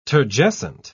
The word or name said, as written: turgescent